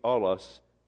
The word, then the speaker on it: allos: The word another here is the Greek word allos, spelled phonetically A-L-L-O-S, allos.